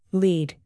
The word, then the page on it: lead: tack